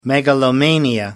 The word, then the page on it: megalomania: American English